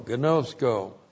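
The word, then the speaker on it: gnosko (γινώσκω): G-I-N long O, S-K long O, gnosko.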